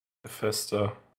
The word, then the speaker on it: Bethesda: Bethesda? – Bethesda.